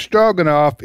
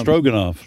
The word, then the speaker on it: Stroganoff: Stroganoff. – And wow. It's spelled, the Russian spelling is Stroganov. – Stroganoff. – But the English, we do it Stroganoff, which is phonic, Stroganoff. But it's Russian.